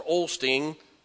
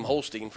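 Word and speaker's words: Osteen: By the way, that's why Mr. Olsteen, yeah, I said it right that time. Keep calling him Holstein for some reason.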